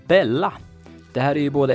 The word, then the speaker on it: Bella: Dubbel L blir ju som bekant J på spanska och av den anledningen så skulle man uttala namnet Bella.